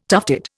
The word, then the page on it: tufted: Indian